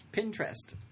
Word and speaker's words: Pinterest: Pinterest. Pinterest? – Pinterest. – Pinterest. – Like pin interest.